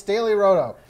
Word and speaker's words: Daily Roto: We're brought to you today by Daily Roto, which I always find a way to say it wrong. It's Daily Roto.